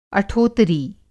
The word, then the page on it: अठोतरी: Hindi